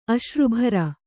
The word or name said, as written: ಅಶ್ರುಭರ